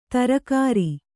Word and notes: ತರ್ಕಾರಿ: tarkāri

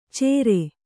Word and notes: ಚೇರೆ: cēre

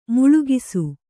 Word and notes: ಮೂಲಗಿಸು: mūlagisu

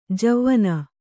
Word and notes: ಜವಾನಿ: javāni

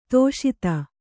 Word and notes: ಧಾಷತ್ತು: kannada

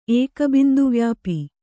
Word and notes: ಏಕಬಿಂದುವ್ಯಾಪಿ: Kannada

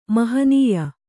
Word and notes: ಮಹನಿೀಯ: Kannada

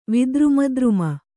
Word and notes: ವಿದ್ರುಮದ್ರುಮ: Kannada

